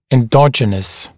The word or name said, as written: endogenous